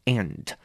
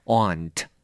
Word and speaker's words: aunt: Aunt 这个字就是伯母啦、婶母啦、阿姨啦、姑妈啦，都叫做 aunt. 不过呢，有的时候你可能会听过，也有人把它念成 aunt. Aunt. 所以都可以哈。